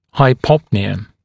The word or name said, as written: hypopnea